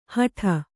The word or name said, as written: ಹಾಡಿ